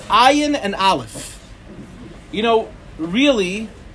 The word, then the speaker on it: Ayin: The right way to say it is a very guttural sound. It's actually an ayin. It comes from the back of the throat.